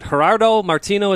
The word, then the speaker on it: Gerardo Martino: Jeff, shifting gears a bit, you had just reported yesterday that Atlanta United is in talks with former Argentinian national team manager, is it Gerardo Martino, is that how you say it? – Yes.